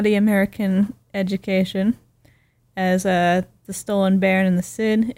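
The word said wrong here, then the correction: Sìth: It is actually pronounced the Stolen Bairn and the Shid or Shade.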